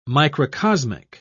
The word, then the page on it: microcosmic: British English